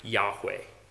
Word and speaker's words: Yahweh: So the proper way to say God's special name was something like Yahweh, we think.